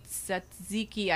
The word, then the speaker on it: tzatziki: So I would be curious to know, like the tzatziki, I can never pronounce that damn word. – Tzatziki. – Yeah, that word.